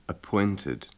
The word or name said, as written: appointed